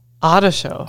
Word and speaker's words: Artio: Ottershow. – Ottershow. – Ottershow? – It's the way you roll your subors. – Yeah. A-R-T-I-O, but it's pronounced Ottershow. – What is that? – A-R-T-I-O.